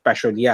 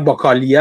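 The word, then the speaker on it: Bachelier: تلفظش فکر کنم تلفظ درستش بشالیه هستش خود من فکر میکنم باکالی است خیلی جورهای مختلف بستگی داره که کی تلفظش کنه تلفظش فرق ولی تلفظ فرانسویش فکر میکنم بشالیه است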